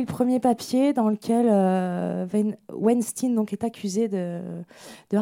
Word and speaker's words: Weinstein: L'idée, c'était de revenir quatre mois après euh, l'affaire euh, Weinstein, parce que c'est comme ça qu'on prononce son nom en vrai, euh, sur voilà, qu'est-ce qui a changé.